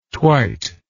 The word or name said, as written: twite